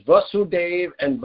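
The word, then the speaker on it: Vāsudeva: So Lord, there's Vasudev and Vasudev. Here it mentions, there's lots of things to mention here. Vasu with a long A, Vasudeva, is Lord Vasudev, the son of Vasudev with a short A.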